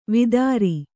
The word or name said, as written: ವೇತ್ರ